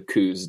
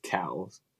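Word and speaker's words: Coues: I haven't hunted them in a couple of years, but that's going to go to the coos deer, which I guess Tim says cows. – Tim's one of those weird guys that say it correctly.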